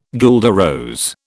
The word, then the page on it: guelder rose: British English